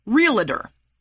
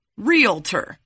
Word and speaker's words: realtor: I never noticed until my husband changed careers a couple years ago and became a realtor, but a lot of people pronounce it realtor. Now, that makes no sense whatsoever because the L is after the A, not before it. Realtor, not realtor.